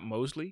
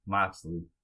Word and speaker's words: Moxley: Not Mosley? – It said M-O-X, right? – Yeah, but X could sound like Z sometimes. – Nah, man. Moxley. – All right. John Moxley.